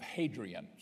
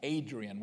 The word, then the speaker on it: Hadrian: Hadrian, sometimes spelled and pronounced Adrian without the H. Hadrian is the one that's the most common.